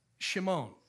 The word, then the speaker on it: Shimon: We pronounce it Simon. Shimon.